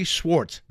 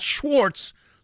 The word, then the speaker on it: Swartz: is jazz bassist Harvey Swartz, not Schwartz, Swartz.